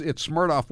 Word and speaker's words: Smirnoff: It's Smirnoff with a V, but I think you have to pronounce it like the vodka.